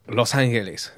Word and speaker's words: Los Angeles: We're not saying it right. – Los Angeles. La ciudad de Los Angeles.